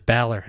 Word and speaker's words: Ballard: Is that pronounced Baller Hardware? – And it's Ballard. I found that out on day one where they answered the phone. I was like, oh, it's Ballard? – Yeah, oof.